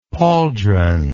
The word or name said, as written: pauldron